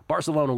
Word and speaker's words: Barcelona: Barcelona, if you pronounce it in the Castellano. Barcelona win plus 125 at Inter Milan.